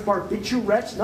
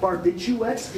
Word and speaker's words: barbiturates: By the way, let's pronounce barbiturates, not barbituates, which drives me crazy.